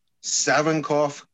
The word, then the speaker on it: Savinkov: You pronounced Savinkov correctly.